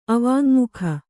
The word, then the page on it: ಅವಾಙ್ಮುಖಿ: avāñmukhi